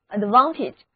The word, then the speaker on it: advantage: advantage 很明显它是一个名词，表示优点或者长处，名词后缀 age 中的字母 a 要读作短的 e。